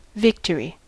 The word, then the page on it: victory: American English